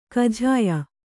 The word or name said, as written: ಕಜಿಯಾ